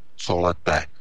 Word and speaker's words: Colette: Takže eh, pokud vás to zajímá, tak eh, ten, že to vyslovování, nebo tady to, tak je to c o l e t t e co lete, je ten film, se jmenuje.